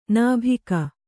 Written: ನಾಭಿಕ

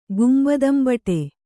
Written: ಗುಂಬದಂಬಟೆ

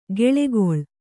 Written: ಕೆಳೆಗೊಳ್

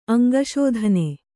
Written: ಅಂಗಸಾಧನೆ

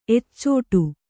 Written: ಎಚ್ಚಾಡು

